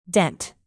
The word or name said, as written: dent